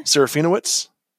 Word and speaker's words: Serafinowicz: Serafinowicz.